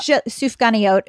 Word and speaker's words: sufganiyot: Yeah, they're like little round sufganiyot is what it's called. – Sufganiyot. – Yeah, I think that's how you pronounce it.